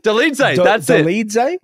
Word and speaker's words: Dolidze: It's like Dolidze? – Dolidze. That's 100%.